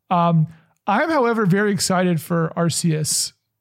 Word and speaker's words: Arceus: Arceus. Yeah, if you're ever around children, make sure you say that right.